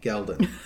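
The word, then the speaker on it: Ghealdan: I know how to pronounce that because Moraine taught me. Geldon.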